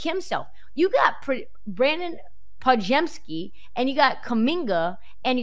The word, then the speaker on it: Podziemski: Podziemski.